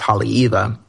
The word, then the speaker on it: Haleiwa: And same like the town that I'm from, that I pronounce as Haleiwa, it's spelt like Haleiwa. But if the W is in the middle of the word, it's actually a V, kind of like Wiesbaden or things like that in German.